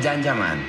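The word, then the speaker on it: Can Yaman: Se escribe Jan Yaman, se pronuncia Jan Yaman.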